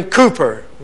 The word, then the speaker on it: Cowper: William Cooper, we spell it C-O-W-P-E-R, but you'll find songs like There is a Fountain Filled with Blood and many others.